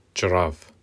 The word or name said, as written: çirav